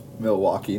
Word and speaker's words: Milwaukee: Milky Way Bucks. – Milwaukee, not Milky Way. Milwaukee Bucks. – Oh, I just love them. Milky Ways. – You love Milky Ways. – Yeah. Sorry. – Sorry, Milwaukee.